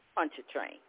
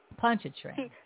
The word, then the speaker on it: Pontchartrain: Oh, crossing Lake Pontchartrain. – Pontchartrain.